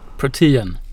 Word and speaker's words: Protean: Protean eller Protean Funds, uttalar jag det rätt? Protean. Protean.